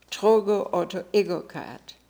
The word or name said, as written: Trogoautoegocrat